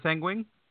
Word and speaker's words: Seguin: It's Tyler Seguin? – S-E-G-U-I-N.